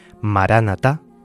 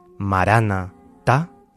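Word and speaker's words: Maranata: Maránata tiene un doble significado según la lectura que hagamos de ella si separamos las dos primeras sílabas, es decir, marán, Ata, se convierte en una afirmación gozosa, una afirmación pletórica, llena de fe, que significa el Señor viene o el Señor ha venido. Esta fórmula aclama la presencia del resucitado en medio de la comunidad cristiana. Pero, si pronunciamos separando las tres primeras sílabas de la cuarta, Marana Ta, la invocación se convierte en un grito de esperanza.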